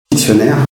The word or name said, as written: dictionnaire